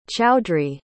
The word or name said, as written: chowdhury